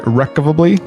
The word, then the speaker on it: irrevocably: First off, how to pronounce the word irrevocably.